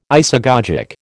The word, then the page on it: isagogic: American English